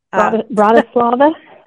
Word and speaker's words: Bratislava: Bratislava.